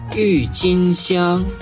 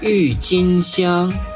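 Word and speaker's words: Юйцинсян (郁金香): Юйцинсян. – Это тюльпаны. У тюльпанов есть красные, розовые, желтые, фиолетовые, невероятно красивые. – Юйцинсян. – Юйцинсян. Тюльпан. Ю читается четвертым тоном, тин – первым тоном, сян – первым тоном.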